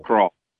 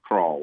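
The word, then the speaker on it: Kroil: And it's pronounced Crawl. Crawl, Crawl, whatever. I call it Crawl, like crawling, but Crawl. – It's like it's oil with a K and an R. Crawl.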